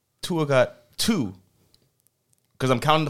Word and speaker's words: Tua: We pronounce it a different way now. – Tua. – Thank you. – He threw Tua.